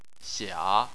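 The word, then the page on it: xia: tone 3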